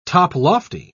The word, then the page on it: toplofty: British English